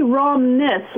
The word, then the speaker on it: Romness: It's actually Romness, like Romney with an S.